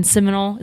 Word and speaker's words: Seminole: So I find this little tiny flower shop in Seminole.